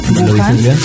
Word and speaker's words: Wuhan: Una nueva cepa fue identificada en Wuhan, ¿sí? ¿Lo digo bien? – Wuhan, China.